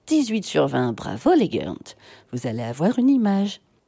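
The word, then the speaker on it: Ghörnt: Et pour l'heure, je vous propose d'écouter les Gornt ou Yernt. Ça s'écrit G-H-O-R-N-T avec deux petits trémas sur le O, comme j'aime. Non pas le truc pour maigrir, mais les petits trémas, un peu comme en Suède.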